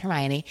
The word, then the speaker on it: Hermione: So now we all know that it's Hermione.